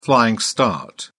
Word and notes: flying start: British English

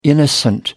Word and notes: innocent: American English

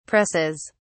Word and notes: presses: British English

